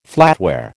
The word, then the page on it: flatware: American English